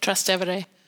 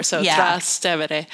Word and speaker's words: Trastevere: So actually in Rome, I went to Trastevere, and I know that that's probably not how you say it. – Trastevere. Yeah. – I can't do it. – It means like across the Tiber. Trastevere.